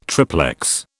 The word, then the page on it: triplex: British English